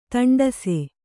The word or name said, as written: ದಾಂಟಿಸು